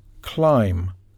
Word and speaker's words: climb: For example, climb, the B isn't pronounced.